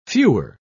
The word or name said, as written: fewer